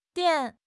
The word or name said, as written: dian3